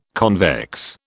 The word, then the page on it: convex: British English